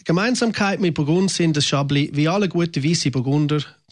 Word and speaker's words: Chablis: Chablis geschrieben, aber Chablis ausgesprochen. Chablis ist einer der bekanntesten Weißweine der Welt.